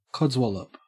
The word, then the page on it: codswallop: Australian English